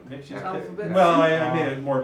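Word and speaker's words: Mxyzptlk: Which is Mixius Piddly.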